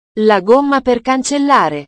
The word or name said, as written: la gomma per cancellare